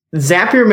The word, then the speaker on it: Zapier: Zapier makes you happier is what we say internally. That's sort of the official pronunciation.